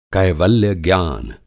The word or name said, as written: कैवल्य-ज्ञान